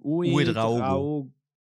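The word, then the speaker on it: Ouédraogo: Wie wird er ausgesprochen? – Uedraogo. – Okay, ja. Danke.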